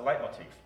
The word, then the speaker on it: leitmotif: It's a leitmotif.